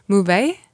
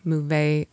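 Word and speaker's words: Möwe: Mouve. – And it's German. – For seagull. And it rhymes with O'Day. My boat's an O'Day. Mouve, O'Day.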